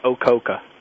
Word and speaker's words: OCOKA: Well, what that means is the acronym stands for, we say the word OCOKA is how we pronounce it.